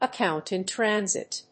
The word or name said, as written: account in transit